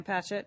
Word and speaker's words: Patchett: Second pick is Ann Patchell's new book, Commonwealth. – Oh, okay. Ann Patchett. Yeah. – Yep. Did I say her name right? Patchett. No, I forgot to cross my T's.